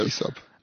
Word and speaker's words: Aesop: Yeah, Aesop.